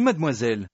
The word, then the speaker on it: Mademoiselle: Oui mademoiselle.